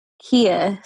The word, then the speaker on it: Keiyaa: Kia. So it's spelled K-E-I-Y-A-A.